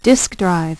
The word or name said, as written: disk drive